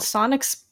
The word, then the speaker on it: Psionic: Yeah, and then they have a 1987 album called Sonic Sunspot. There's two silent P's in front of Sonic and Sunspot, so that was confusing. – It's the PP album, Psionic Sunspot, spelled with P's.